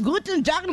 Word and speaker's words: Guten Tag: Guten Tag, lo dije bien. – Hola. Soy sí. – Shona de Alemania y el saludo está muy bien pronunciado. – Ay, muy bien.